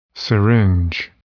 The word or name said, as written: syringe